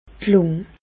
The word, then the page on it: d' Lùng: Bas-Rhin; Reichshoffen